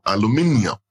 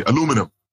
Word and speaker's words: aluminium: Bauxite is the raw material for aluminium, or as the Americans say, aluminum, which makes drinks cans and it makes aircraft.